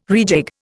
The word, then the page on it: rejig: Indian